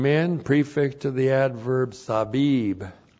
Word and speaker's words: sabib: The preposition men means out from, and then the adverb sabib, sab. Sabib. S-A-B long I-B. Sabib.